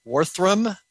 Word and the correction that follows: Waltham: Worthrum? And that in Waltham? How do you say that? – Waltham. – Waltham. Oh, okay. Waltham.